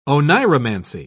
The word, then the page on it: oneiromancy: British English